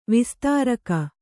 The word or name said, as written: ವಿಸ್ತಾರಕ